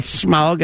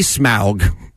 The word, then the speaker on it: Smaug: Not Smaug, as mispronounced by the layman. – Okay. – It be Smaug.